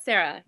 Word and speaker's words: Sarah: Is it Sarah Bray or Sarah? – Sarah, yeah.